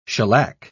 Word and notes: shellack: British English

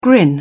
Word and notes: grin: British English

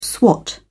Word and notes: swat: British English

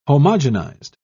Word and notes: homogenised: British English

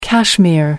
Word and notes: cashmere: American English

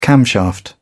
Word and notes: camshaft: British English

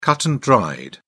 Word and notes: cut-and-dried: British English

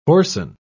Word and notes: hoarsen: British English

